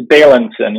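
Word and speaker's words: Bailenson: In his book, Experience on Demand, VR expert Jeremy, how do you pronounce his last name? Balinson? – I think it's Balinson, yep.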